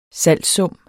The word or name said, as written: salgssum